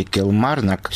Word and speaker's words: Килмарнок: установленный в шотландском городе Килмарнок в середине 90-х, будет демонтирован. Конец цитаты. Подчеркну, кстати, для тех, кто не знает, что ударение в названии этого города надо ставить на втором слове.